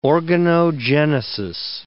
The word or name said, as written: organogenesis